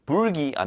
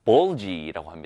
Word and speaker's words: bulgy: 자, 요거 발음 불기 아니고요. 불고기처럼 불기 아닙니다. bulgy라고 합니다.